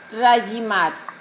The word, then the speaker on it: Rajimat: A-R-A, lleva tilde en la última A, Mebucará. – Mebucará. – Mebucará. – Rajimat. – R-A-J-I-M-A-T. Rajimat. Rajimat significa incremento, aumento de prosperidad, de abundancia, multiplicación. Eso significa Rajimat. Aumento de prosperidad es una palabra que Rajimat.